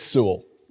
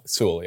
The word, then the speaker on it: Sewell: It's Sewell. It's just like Sewell. – Sewell, yeah. Just kind of flow through all the letters and it just becomes one mush.